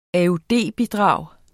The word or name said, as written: AUD-bidrag